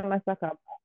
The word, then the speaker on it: Masaka: You know Masaka? I'm probably saying it wrong. – No. You said it right.